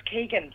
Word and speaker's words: Keegan: That is the unforgettable voice of Phil Hotstuff Coogan. Keegan? How do I say it? – Well, it's Keegan, but given the fact that he kind of has this hybrid accent, I think maybe you could take a variation on Keegan.